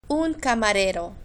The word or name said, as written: un camarero